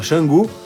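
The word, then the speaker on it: Canggu: É, como a gente lá ficou instalado em Xangu, que na verdade se escreve Cangu, mas se fala Xangu,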